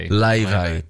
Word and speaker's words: Laufey: Laufey. – But she's spelled L-A-U-F-E-Y.